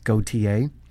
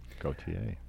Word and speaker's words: Gauthier: I assume he pronounces it the French way. Gauthier. – Gauthier.